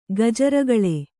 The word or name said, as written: ಗಜರಗಳೆ